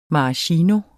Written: maraschino

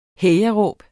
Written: hejaråb

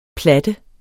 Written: platte

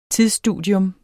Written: tidsstudium

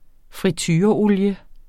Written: fritureolie

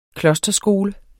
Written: klosterskole

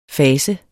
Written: fas

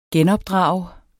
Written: genopdrage

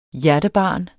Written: hjertebarn

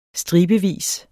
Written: stribevis

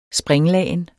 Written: springlagen

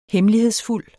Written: hemmelighedsfuld

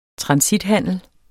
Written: transithandel

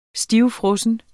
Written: stivfrossen

